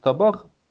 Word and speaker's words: табак: тут еще, еще одно вот фонетическое явление – Значит, э, вот у нас есть табак, это тарелка. И ударение у нас на А. Табар, да? – На вторую. – На вторую а.